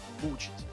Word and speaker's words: бучить: Ударение на бучить.